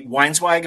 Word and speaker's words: Weinzweig: So you're visiting Ari Weinzweig. Am I pronouncing that correctly? – Yep.